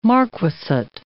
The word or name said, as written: marquessate